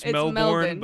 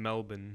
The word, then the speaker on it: Melbourne: Okay, folks, it's Melbourne, but she's talking about. She's saying Melbourne. – Melbourne. That's how the guy said to pronounce it. And I have heard it before, even though, yeah, it looks like you would say Melbourne, and which is how I've always said it in my head.